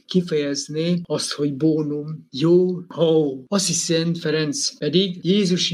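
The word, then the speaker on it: hao (好): Egy másolhangzóval és egy diftangussal próbálom kiejteni, hao, ez kínaiul a jó.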